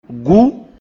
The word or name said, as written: Gú